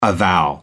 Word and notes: avow: American English

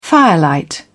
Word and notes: firelight: British English

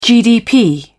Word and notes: gdp: British English

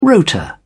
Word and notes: rotor: British English

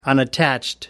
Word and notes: unattached: American English

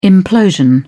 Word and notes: implosion: British English

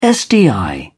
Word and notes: sdi: British English

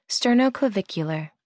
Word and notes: sternoclavicular: American English

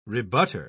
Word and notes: rebutter: British English

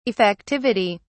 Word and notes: effectivity: British English

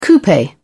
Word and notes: coupe: British English

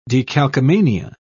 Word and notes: decalcomania: British English

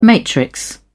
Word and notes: matrix: British English

